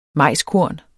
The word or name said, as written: majskorn